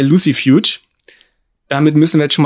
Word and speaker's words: Lucifuge: Und Lucifuge ist Latein. Und deswegen müsste man es Lucifuge aussprechen. Mein Lateinlehrer und der andere Lateinlehrer haben immer gesagt, C wird wie K ausgesprochen. – Ja, habe ich auch so gelernt. – Kein Mensch sagt Cäsar, sondern Cäsar. Also würde ich es gerne weiter in Englisch aussprechen.